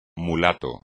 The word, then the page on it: mulato: espagnol